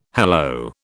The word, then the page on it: hello: British English